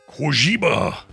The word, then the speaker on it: Kojima: Kojima is how you say it.